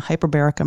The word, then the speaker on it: hyperbaric: And I'm thinking, wow, there are so many different characters in this book, which is about a tragic accident in a hyperbaric, am I saying that correctly? – Yes, you are.